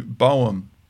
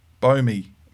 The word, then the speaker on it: Boehme: Hosted by Ron Bo- Boehm. Boehme.